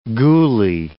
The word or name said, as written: ghoulie